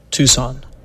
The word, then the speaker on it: Tucson: Tucson, Tucson, Tucson.